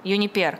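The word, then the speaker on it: Uniper: Крупнейший немецкий газовый импортер Юнипер, правильно я ударение поставила, кажется? – Юнипер.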